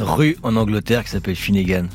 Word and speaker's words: Finnegan: Est-ce que je prononce bien votre prénom, Finnegan? – Finnegan, ouais. Finnegan, c'est bien. Finnegan à la française, Finnegan.